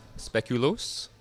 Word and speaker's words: speculoos: How do you pronounce it? – Speculoos. Speculoos. Probably about four or five different ways, depending upon who the person is. – Those wacky Belgians. – Belgians, Flemish, the French. Here, we pronounce it as speculoos.